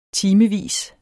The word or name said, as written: timevis